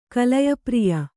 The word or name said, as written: ಕಲಯಪ್ರಿಯ